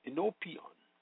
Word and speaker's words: enopion: The Greek word for presence is enopion, pronounced enopion, meaning in the face of, before, in the presence, sight of.